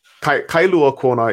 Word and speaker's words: Kailua Kona: Kailua Kona is the main city on the west side of the island.